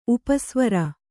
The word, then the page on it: ಉಪಸ್ವರ: upa svara